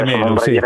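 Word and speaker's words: Romelu: Ho letto anch'io la copertina a Remelu, come si pronuncia. – Remelu.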